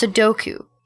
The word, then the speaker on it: Sudoku: Sudoku? – No, ma'am. It's pronounced Sudoku. It's a Japanese number game.